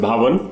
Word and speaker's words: Bhavan: So I'm hoping are you saying Americans are able to pronounce it Bhavan? Bhavan? – Yeah. I mean, okay. Put the H is silent and they're able to get it right. – Okay. Bhavan.